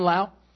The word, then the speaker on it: Lowe: My name is John Lowe. It's spelled L-O-W-E. And looks like low, pronounced funny.